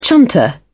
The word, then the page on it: chunter: British English